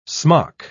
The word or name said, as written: smock